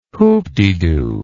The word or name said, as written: hoopdedo